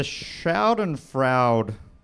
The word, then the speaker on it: Schadenfreude: The Schadenfreude... How do you say Schadenfreude? – Schadenfreude! – Thank you.